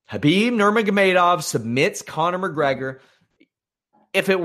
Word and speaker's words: Khabib Nurmagomedov: Oh, you when you hear the mainstream press accounts of this, listen to how they pronounce his name. If they pronounce his name as anything but Khabib Nurmagomedov, change the channel.